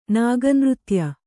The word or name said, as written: ನಾಗನೃತ್ಯ